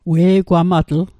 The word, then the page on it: we'gwamatl: Mi'gmaq